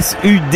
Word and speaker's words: Sud: alors je sais que parfois on nous appelle SUT Radio, mais on a reçu des SUT. Ouais. Alors c'est SUD. – Ouais, voilà. Mais ça c'est un hommage à tous ceux qui ont cette petite prononciation, voilà, du côté du Tarn ou de l'Ariège, où on prononce les D comme des T.